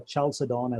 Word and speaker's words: Chalcedon: It was called Council of Chalcedon, or Chalcedon as some people pronounce it.